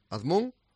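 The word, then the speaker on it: Azmoun: ¿Cómo se pronuncia? Azmún, ¿no? Uh-huh. ¿Azmún? – Sí, tal cual, Sardar Azmún.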